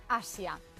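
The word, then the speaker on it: Hazia: La pareja ha elegido un nombre en euskera que significa semilla y se pronuncia Asia.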